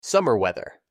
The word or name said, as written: Summer weather